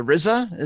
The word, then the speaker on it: RZA: Wi-Fi, to the RZA. Is that how we say his name? – Yeah.